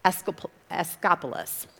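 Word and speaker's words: Asklepios: One main one, their big moneymaker, was, and it doesn't look like to me like it's pronounced like this, but everybody that I listened to pronouncing this and read pronunciation says Escapulus. So I'm going to call him Escapulus, but it looks like there's a few more letters in there.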